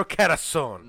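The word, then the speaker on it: Carosone: Renato Carasone.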